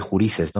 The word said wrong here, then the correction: gurises: ¿no? Que es como... – Gurises. Gurises. gurises.